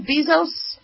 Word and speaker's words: Bezos: Is that how you pronounce it, Bezos? Yeah.